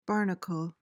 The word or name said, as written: barnacle